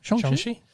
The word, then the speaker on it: Shang-Chi: Abomination, Wong, and other surprise cameos in Marvel Studios' Shang-Chi. – Yeah. – Did I say that right? – Yeah. – Shang-Chi.